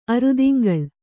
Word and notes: ಅರೆದಿಂಗಳು: arediŋgaḷu

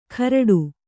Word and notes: ಕರಟು: karaṭu

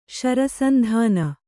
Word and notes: ಶರಸಂಧಾನ: kannada

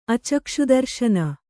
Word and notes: ಅಚಕ್ಷುದರ್ಶನ: Kannada

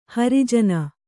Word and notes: ಹರಿಜನ: Kannada

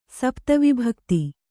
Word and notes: ಸಪ್ತವಿಭಕ್ತಿ: kannada

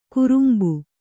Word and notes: ಕುರುಂಬು: kurumbu

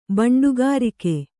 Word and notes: ಬಂಡುಗಾರಿಕೆ: baṇḍugārike

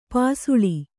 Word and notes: ಪಸಲು: Kannada

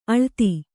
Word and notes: ಅಳೆತ: aḷeta

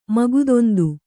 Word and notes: ಮಗದೊಂದು: Kannada

